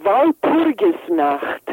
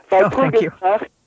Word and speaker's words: Walpurgisnacht: Okay, so Walpurgisnacht, that's how you say it. – Walpurgisnacht